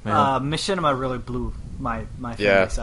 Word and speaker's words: Machinima: Hey, Machinimas. – Yeah, no, it's pronounced Machinima.